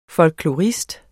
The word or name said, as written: FOLKLORISTER